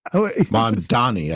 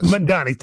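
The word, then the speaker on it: Mamdani: You mentioned the Mandavi. Is it Mandavi? – Mandani, I think it is. – Mandani, thank you. I was like, there's something wrong with my pronunciation.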